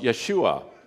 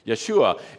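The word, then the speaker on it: Yeshua (ישוע): There is a time to act, and that is the meaning of Jesus' given name, Yeshus, or in Hebrew and Aramaic, the name as he was called in his own language, it would have been pronounced Yeshua, which is the same name as Joshua in English. Yeshua.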